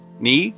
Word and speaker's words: Knee: Knee, joelho, lembre-se, K antes do N em inglês nunca é pronunciado.